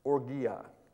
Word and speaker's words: panourgia: It's spelled P-A-N-O-U-R-G-I-A, panorgia.